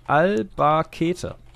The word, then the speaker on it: Albacete: Spricht sich hundertprozentig anders aus. Albacete.